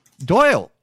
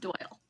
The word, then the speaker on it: Doyel: Doyle. – Okay, so I did get it right the first time. – We spell it a little funny. It's spelled phonetically, but it's still pronounced Doyle.